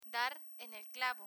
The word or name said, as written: dar en el clavo